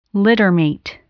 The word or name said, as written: littermate